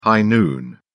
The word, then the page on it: high noon: British English